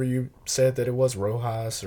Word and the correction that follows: Rioja: or whatever. – Right, Rioja.